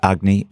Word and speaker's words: Agni: Am I pronouncing this right? Agni, the digestive. – Agni. – Uh-huh. – Yeah.